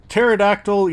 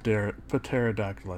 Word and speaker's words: pterodactyl: Today's movie monologue is pterodactyl urinal cakes. The P is silent. Pterodactyl, I said. You know why I said pterodactyl? Because IRL, when I say that word, I purposely pronounce the P, but for the purposes of the sponsor, I shouldn't have, so I fucked up.